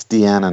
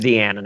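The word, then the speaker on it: Dianna: Diana Russini. – It's Deanna now. – Yeah. – Oh, it is Deanna now. Okay. Well, it's spelled like Diana with an extra N.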